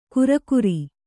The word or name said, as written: ಕುರ್ಕುರಿ